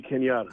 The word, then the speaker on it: Kenyatta: Stogie, give me your last name because I think I'm saying it wrong. – Stogie Kenyatta.